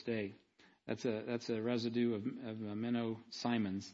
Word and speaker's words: Menno Simons: But Menno Simons, Menno Simons is how it's pronounced, I think, properly. That's how the Mennonites say it.